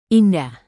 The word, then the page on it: indah: indonesian